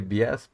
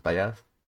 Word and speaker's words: Bias: Y Sí, o sea, se escribe Bias, pero es Bias.